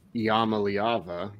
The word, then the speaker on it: Iamaleava: Uh, the biggest one yesterday, five star quarterback Nico Iamaleava, which is how his dad told me how to say the name.